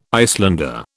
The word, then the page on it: Icelander: British English